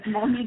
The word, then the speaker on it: Mony: It is Mony as exactly you pronounced it.